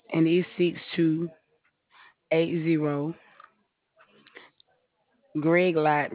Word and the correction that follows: Grelot: Road, which is Greelight Road. I'm sorry. It's spelled G-R-E. L-O-T Road, Mobile, Alabama.